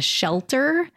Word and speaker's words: Schellter: I also tried the Edna's IPA at the Schellter. Yeah. So it's not like shelter spelled like shelter, but Schell beer. S C H E L L T E R.